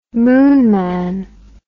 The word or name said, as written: moonman